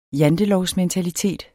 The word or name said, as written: jantelovsmentalitet